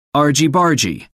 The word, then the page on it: argy-bargy: American English